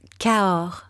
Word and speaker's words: Cahors: Cahors.